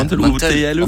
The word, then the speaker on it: Mantel: Moi, je mettrais M-A-N-T-E-L, je dirais. Voilà. Mantel. – En fait, c'est Mantel, mais il faut manger la dernière syllabe.